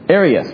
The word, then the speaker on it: Arius: Is it Arius? – Arius, yes. Just one extra syllable. Arius. Arius with an A.